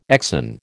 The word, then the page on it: exon: American English